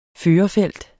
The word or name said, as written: førerfelt